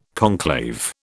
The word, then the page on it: conclave: British English